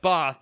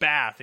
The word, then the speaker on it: Bath: They say it like Bath, but they're snobby English people. We say it Bath in Canada.